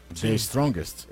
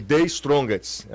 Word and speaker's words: The Strongest: O pessoal tá achando ruim aqui a minha pronúncia, tá? – The Strongest. – The, the, Strongest. É assim? – Strongest. The Strongest. The Strongest. – Strongest. – É Isso.